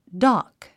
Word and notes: dock: dάk; ダク

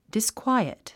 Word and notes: disquiet: diskwáiət; ディスクワイエット

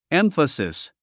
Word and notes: emphasis: émfəsis; エンファスィス

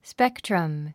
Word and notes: spectrum: spéktrəm; スペクトラム

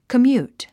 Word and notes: commute: kəmjúːt; コミュート

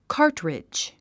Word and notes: cartridge: kάː r tridʒ; カートリッジ

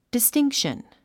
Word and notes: distinction: distíŋkʃən; ディスティンクション

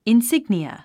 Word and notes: insignia: insíɡniə; インスィグニア

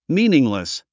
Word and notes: meaningless: míːniŋlis; ミーニングレス

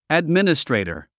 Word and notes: administrator: ædmínəstrèitər; アドミニストレイタァ

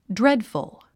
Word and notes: dreadful: drédfəl; ドレッドフゥ

